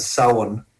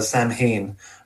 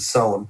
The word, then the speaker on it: Samhain: You know, he has that beautiful speech about, as we say in Ireland, Samhain. I know the Americans call it Samhain, but we pronounce it Samhain, and he pronounces it perfectly.